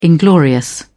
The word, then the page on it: inglorious: British English